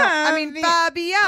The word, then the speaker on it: Fabiano: Peter Fabriano. Fabiano. – Oh my God, she said it wrong again.